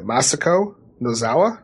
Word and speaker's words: Masako Nozawa: Yeah, Masako Nozawa? – Yeah, you got it.